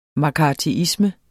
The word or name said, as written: mccarthyisme